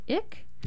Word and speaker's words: Icke: And it's a new play by, am I pronouncing this right, Robert Icke? – Yes.